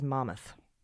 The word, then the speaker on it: Monmouth: wants us to know that the way a New Jerseyan pronounces Monmouth is Mammoth. Mammoth.